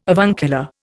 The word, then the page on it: avuncular: Indian